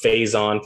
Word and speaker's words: Faison: Kevin Wynn, he said it's pronounced Faison for Brendan Faison.